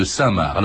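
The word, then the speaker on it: Cinq-Mars: Je précise aussi que on dit parfois 5 Mars, parce que ça se prononce comme ça, c'est INQ comme le chiffre et Mars comme la planète, mais ça se prononce Saint-Marc, François Zéril Descheimer.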